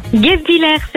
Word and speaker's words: Guebwiller: Guebwiller, c'est ça.